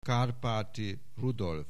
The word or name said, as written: Kárpáti Rudolf